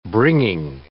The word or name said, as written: bringing